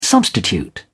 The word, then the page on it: substitute: British English